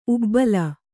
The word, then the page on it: ಉಬ್ಬಲ: ubbala